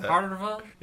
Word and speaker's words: Favre: That's how you pronounce that. – Favre? – Yeah, exactly.